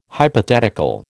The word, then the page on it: hypothetical: American English